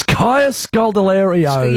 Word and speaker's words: Kaya Scodelario: It's Kaya Scodelario.